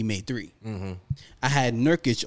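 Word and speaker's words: Nurkić: Nurkic? – Mm-hmm. Yusuf Nurkic.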